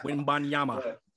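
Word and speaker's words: Wembanyama: Wimbanyama.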